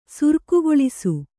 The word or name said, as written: ಸುರ್ಕುಗೊಳಿಸು